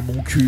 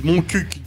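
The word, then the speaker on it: Montcuq: Je crois que le fameux Moncul. – Ah bah oui, le fameux. – Certes. – Qui s'écrit Moncuc. – Oui. – Et ils insistent pour qu'on dise Moncuc, d'ailleurs.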